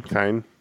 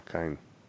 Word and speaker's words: Cian: Kine. If it's Irish, that's a hard C usually. – Yeah, Kine.